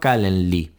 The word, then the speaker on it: Calendly: que se llama Calendly. Calendly eh, se escribe Calendly, L-Y final.